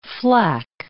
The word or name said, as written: flack